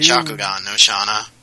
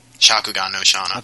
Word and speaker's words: Shakugan no Shana: As far as other licenses, Darker Than Black Season 2, Shingakukan no Shana Season 2. – Shakugan no Shana. – Whatever. How do you say it? – Shakugan no Shana.